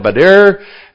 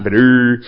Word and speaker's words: Badr: In 624, there was the Battle of Badr. I think that's how you pronounce Badur. They need to put a vowel in there somewhere.